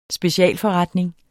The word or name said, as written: specialforretning